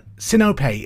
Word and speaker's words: Sinope: Number seven, Sinope is a moon of which planet in the solar system? I'll spell it just in case I've pronounced it wrong. S-I-N-O-P-E. Sinope. Question number seven, Sinope, S-I-N-O-P-E, is a moon of which planet in the solar system?